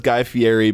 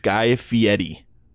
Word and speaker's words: Fieri: So, everyone says Guy Fieri, but it's Guy Fieri.